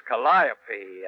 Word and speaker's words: Calliope: Well, according to Webster, it's pronounced Calliope after one of the Greek muses, but actually, Webster never played one.